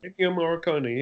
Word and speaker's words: Ennio Morricone: I- Ennio Morricone, is that how you say it? – Ennio Morricone, yes.